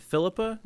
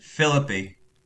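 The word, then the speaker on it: Phillippe: So today we are going to be talking about the 1999 drama Cruel Intentions, starring Ryan Philippa. Is that correct? Is that how you pronounce that? – Philippi. – Philippi. Sorry, my apologies.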